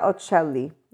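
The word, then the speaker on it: Occelli: Cynthia Ocelli, and I don't even know if she's Italian, but I pronounce it with an Italian accent, but it's O-C-C-E-L-L-I, in case any of my English speakers here are more interested in pronouncing it in the English accent.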